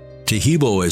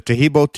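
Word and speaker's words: Taheebo: Taheb is spelled T-A-H-E-E-B, like boy, O, then the word tea, and then the word club.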